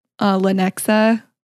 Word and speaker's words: Lenexa: So it went from Utah to North Platte and then it goes to usually, Lenexa.